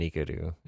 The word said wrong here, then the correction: Nikuko: Am I saying it wrong now? – You're saying it wrong. – I knew this was going to happen. – Nikuko. – Oh, Nikuko and Kikuko.